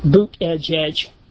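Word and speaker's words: Buttigieg: Had to look up how to pronounce it, boot edge. Now that's how his husband says you're supposed to pronounce his name, so I guess he knows.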